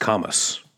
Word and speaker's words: Camus: I believe it's pronounced Camus.